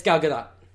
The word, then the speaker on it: Gal Gadot: It's Gal Gadot. – Really? – Really? – Israeli. Yeah. – Oh, huh. – I've seen multiple interviews where she says her name.